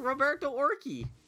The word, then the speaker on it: Roberto Orci: Roberto Orci.